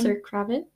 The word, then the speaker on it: Zoe Kravitz: Zoe Kravitz.